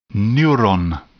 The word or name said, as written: neuron